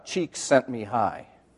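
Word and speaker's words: Csikszentmihalyi: It's actually pronounced Cheeks Sent Me High.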